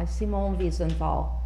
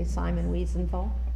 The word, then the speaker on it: Simone Wiesenthal: on the possibilities and limits of forgiveness by Simone Wiesenthal. Some of you may know that name. Some of you would probably say Simon Wiesenthal, but that wouldn't be the correct pronunciation.